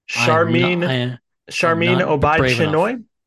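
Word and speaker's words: Sharmeen Obaid-Chinoy: Yeah, and it's interesting because so how do you pronounce the name of the director of this? Charmin Obaid-Chinoy? – I think you just nailed it.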